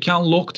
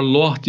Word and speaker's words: Loach: Bazıları soyadını işte Ken Loach diye telaffuz eden var, Loach diye telaffuz eden var.